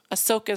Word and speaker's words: Ahsoka: Ahsoka is her name.